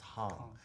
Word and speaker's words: Hang: It's spelled hang, like in English, you know, just mm -hmm. hang. – But it's in Swiss German, it's pronounced hang, and it means playing with.